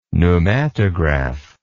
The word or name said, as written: pneumatograph